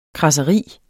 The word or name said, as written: kradseri